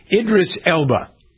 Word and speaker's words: Idris Elba: I never pronounced this guy's name right. Idris Elba. – Yeah, sounds perfect.